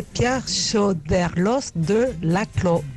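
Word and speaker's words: Pierre Choderlos de Laclos: Atentos todos, que vais a aprender a pronunciar bien Chordelos de Laclos. – Hola, se dice Pierre Chordelos de Laclos.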